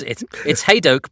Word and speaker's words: Hadoke: But it's Hadoke, but it's a made-up name. I think any pronunciation is okay.